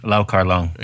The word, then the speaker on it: Lau Kar Leung: Lau Kar Leung.